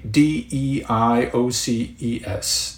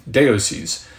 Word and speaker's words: Deioces: That is written out D E I O C E S, Deioces. That's the best pronunciation you'll get from me on that.